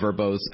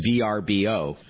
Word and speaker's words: Vrbo: If I'm not mistaken, Vrbo was once pronounced VRBO. – Yeah, that's right. So we recently changed both the branding, the logo of Vrbo, and the pronunciation. As you said, it used to be called VRBO, and now it's called Vrbo.